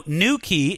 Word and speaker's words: New Quay: Don't think that's spelled, you know, N-E-W-K-E-Y. No, New Quay is spelled N-E-W, and then in a separate word, Q-U-A-Y. But it's pronounced New Quay.